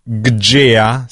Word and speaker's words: Gjea: Rimaniamo vicino a Verona, rimaniamo in tema, perché c'è questo progetto molto particolare di, adesso non saprei neanche come pronunciarlo, Gjea, scritto G-J-E-A, Gjea, Gjea, diciamo che è un po' così, un po' brasiliano, un Gjea.